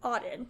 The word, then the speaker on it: Audyn: Like Auden, A-U-D-Y-N, Auden.